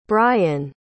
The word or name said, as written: brien